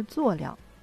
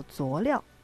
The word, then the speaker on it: 佐料: 说到佐料，很多人都不明白这个词儿，我们来解释一下：作业的作，调料的料，而加在一起呢，它的后边的这个音呢是念轻声的，也就是佐料。但我们的一些城市、一些地方呢，会把它念成叫做佐料，其实也都是可以的。